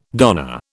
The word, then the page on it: darner: British English